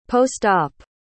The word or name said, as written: post-op